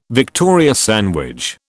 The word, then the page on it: Victoria sandwich: British English